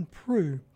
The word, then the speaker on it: Proulx: Before that, we heard from the very talented pianist and vocalist John Prue from his album Baker's Dozen, Remembering Chet Baker. And we heard John do I Remember You, great tune. And John Prue's last name is spelled P-R-O-U-L-X.